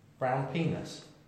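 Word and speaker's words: Brown Pinas: Is that how you pronounce it? Or is it Peanut? Is it Pinar? – No, it's Brown Penis, mate. Obviously, P-I-N-A-S.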